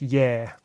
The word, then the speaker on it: Gaia: Now, if you're looking for it on the shelf, it looks like it's written Gaia. But my pronunciation was corrected by a Greek. It's Yer. But Gaia is how it looks. – So that's G-A-I-A.